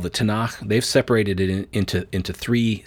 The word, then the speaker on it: Tanakh: And that's the letters T, N, K, which you pronounce Tanakh.